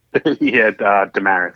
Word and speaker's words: Desmarais: Desmarais.